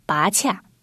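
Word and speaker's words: 菝葜: 它是一种无咖啡因的碳酸饮料，主要原料采用墨西哥拔恰，这个词我们很少使用，是拔起的拔加上草字头，团气的气加上草字头，念作拔恰，俗称金刚藤。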